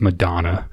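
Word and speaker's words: McDonagh: McDonough. He wouldn't be on there. Or McDonough, some Irish. – It's pronounced Madonna.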